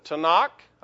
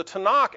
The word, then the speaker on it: Tanakh: And I may have some mispronunciations here as we go, but the Tanakh, I believe is how they pronounce that. Sounds close to me. T-A-N-A-K.